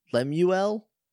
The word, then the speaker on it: Lemuel: I don't know if I would give it the full name, because I'm still not sure if I pronounce Lemuel. It's like Samuel, but instead of Sam, it's Lem. – Lamuel.